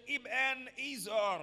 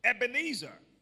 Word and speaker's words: Ebenezer: You can also say Ebenezer, but for ease of pronunciation, Ebenezer, saying, hitherto has the Lord helped us.